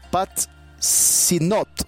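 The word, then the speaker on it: Pajitnov: Dice que es el Alepsei Patsitok, así, ¿no? Patsinot, así es.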